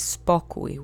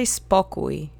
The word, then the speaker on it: spokój: The first word that comes to mind is spokój, which means the state of calmness or peace. Let's say spokój together three times. Accent is on the first syllable. Spokój. Spokój. Spokój.